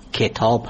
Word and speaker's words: کتاب: به طور مثال تلفظ درست واژه کتاب، کتاب هست، یعنی با کسره کاف تلفظ میشه نه با فتح.